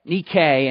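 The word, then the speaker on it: Nike (νίκη): The word overcome, which appears twice in verse 21, is the Greek word nike. Nike, and how you would pronounce it today in modern Greek is Nike.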